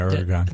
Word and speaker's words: Erdogan: Yeah, Erdogan. – Did I say it right? Yeah.